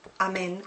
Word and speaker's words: Amen: E la parola è Amen, Amen con l'accento sulla E.